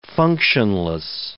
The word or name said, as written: functionless